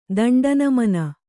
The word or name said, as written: ದಂಡನಮನ